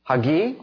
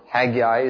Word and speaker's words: Haggai: It shows up in the prophet Haggai, or Haggai as you might pronounce it, and talking about the dedicating of a temple with a greater glory than the one that went before.